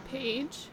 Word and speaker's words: Pahge: Do you know how to pronounce this name? The first name is P-A-H-G-E, Nola Jones. – Page. – Yeah, maybe.